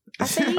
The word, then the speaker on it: acai: Acai? – I could be wrong. – Mm-hmm. – Okay. And Andy? – The crunch berry? – Right. I believe the correct pronunciation is acai berry.